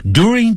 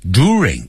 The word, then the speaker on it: during: During times of war， during 不要念成 during，在美式英语要念成 during，在战争的时刻啊，好几次的时刻，所以 time 加了 s， people lose their homes，大家会失去他们的家园 jobs 工作 and their families are broken，然后家庭呢都破裂了，所以都战争可怕。